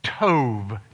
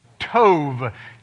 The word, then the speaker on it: tov: But the word good, tov, can you say that with me? Tov. It's actually T-O-B, but in Hebrew, the B is pronounced like a V. Tov, T-O-V.